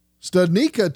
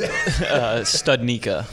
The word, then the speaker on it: Studnicka: I did want to get that interview with Jack Studnicka to you, and we started off going over how he pronounces his last name because, yeah, you hear it pronounced many different ways. – Yeah. – Yes. – Studnicka. – Studnicka. Or Studnicka. Because people say Studnicka, and then they go Studnicka.